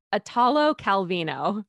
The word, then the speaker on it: Italo Calvino: And it was always stuff like Italo Calvino. I say it right half the time. It's Italo Calvino. There, I think that's right.